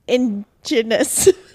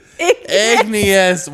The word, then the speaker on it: igneous: I don't know how to say this word. Don't roast me. Ingenious. – Igneous.